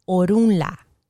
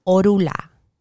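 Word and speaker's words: Orunmila: Another word, another name for orumila would be orunla. Orunla. So I've heard orunla, which is still fine. Orunla. And in Spanish, you will hear orula. Like the N for some reason disappears in the pronunciation.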